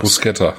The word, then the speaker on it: Bruschetta: wie spricht man das eigentlich richtig aus? Bruschetta. Bruschetta, danke.